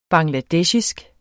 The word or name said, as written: bangladesher